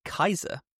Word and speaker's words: Caesar: But if you are affiliated with the Legion, people call them Kaiser, which is the proper actual way to pronounce Caesar, the Latin way to pronounce Caesar.